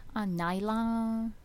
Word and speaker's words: Najla: Najla, Najla.